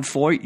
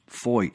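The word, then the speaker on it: Feucht: So Sean Foyt, you spell it F-E-U-C-H-T, F-E-U-C-H-T, but it's pronounced Foyt, F-O-Y-T, Sean Foyt.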